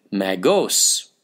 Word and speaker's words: Magos: The Greek word is actually Magos. Don't know if we're pronouncing it correctly, but its spelling is M-A-G-O-S.